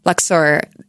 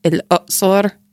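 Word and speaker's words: El-Uqsor (الأقصر): And Luxor, and this is fun, it's that it's not actually pronounced like that at all in Egyptian Arabic. It seems like really far. It's like El-Uqsor.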